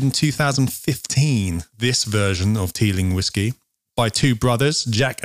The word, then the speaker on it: Teeling: And we're going to try the Teelin Small Batch Irish whiskey. – Yeah, we are. – Have I pronounced that right? – Teeling. Yes.